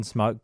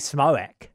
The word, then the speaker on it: Smoak: And I have always assumed it's Evan Smoke, but technically it is Evan, and the surname is spelled S-M-O-A-K. So it could be Smoak. Evan Smoak? No, I think we're going to stick with Smoke.